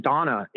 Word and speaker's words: Dana: And on top of that, they operate on Dana. Hmm. Or I'm sorry, I guess it's pronounced Donna.